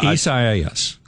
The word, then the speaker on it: Isaias: Isaias is the name of the hurricane. Did I do it right? – You got it. Yeah, Isaias.